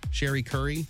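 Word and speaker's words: Cherie Currie: So the gal who's the lead singer for The Runaways, Cherry or Sherry? Sherry Curry. It's spelled like Cherry, but I think I know.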